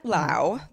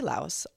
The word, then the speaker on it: Laos: In Laos, the country, and that is how it's pronounced, but people say Laos, also fine, but you know, whatever.